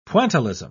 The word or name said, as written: pointillism